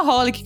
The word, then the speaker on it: xxxHOLiC: E é só Holic que pronuncia.